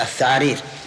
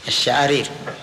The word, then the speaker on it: الشعارير: اي كانه نطق بها هكذا الثعارير يبي يقول الشعارير